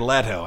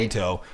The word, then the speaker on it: Leto: Jared Leto. I said right. Leto. – I have a student named Leto, and it's spelled like Leto. That's the reason I did that.